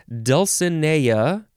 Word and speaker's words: Dulcinea: Dulcinea, you could say dulcinea or dulcinea.